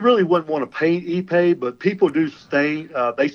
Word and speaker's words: Ipe: That's spelled I-P-E. It's pronounced ePay, and yes.